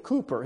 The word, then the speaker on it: Cowper: of a man by the name of William Cooper. His name is spelled C-O-W-P-E-R, but it's William Cooper, and he was a hymn writer.